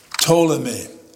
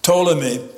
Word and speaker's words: Ptolemy: Ptolemy, the P is silent when you pronounce it. If you don't keep it silent, it'll sound funny. Ptolemy and his descendants ruled over Egypt, whereas Seleucus and his descendants ruled over Syria and Mesopotamia.